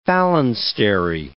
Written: phalanstery

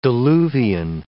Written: diluvian

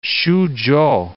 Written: Xuzhou

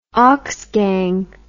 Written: oxgang

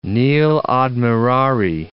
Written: nil admirari

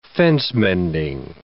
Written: fence-mending

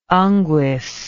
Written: unguis

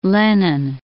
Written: Lenin

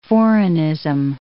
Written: foreignism

